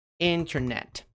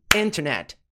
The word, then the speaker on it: internet: Lembrando que, ó, onde que é a sílaba tônica? In, internet, internet. Não é internet, internet. Não é internet. Então, como a sílaba tônica não é a sílaba com T, esse padrão de pronúncia se aplica. Então, internet, sem o T, fica internet, internet, internet, internet, internet, internet, internet, internet, internet.